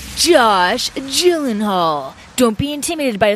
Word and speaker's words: Gyllenhaal: It might be Gyllenhaal. – No, it's definitely Gyllenhaal. – The actor goes by Gyllenhaal.